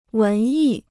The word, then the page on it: 文艺: wén yì